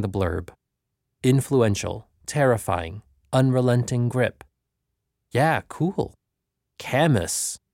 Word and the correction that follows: Camus: I said aloud, butchering the name. It's French, man. Camus.